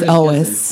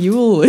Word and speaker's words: Elwes: So also, I've been mispronouncing his name my whole life. – Gary Elwes. – Elwes, dear. – It is? – It's Elwes. – Why have we been saying Yule? – I know.